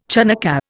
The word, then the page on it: Chanukkah: Indian